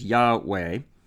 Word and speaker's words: Yahweh: Now that's the four consonants, which the closest pronunciation we can get to, I think, is Yahweh.